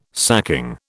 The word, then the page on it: sacking: British English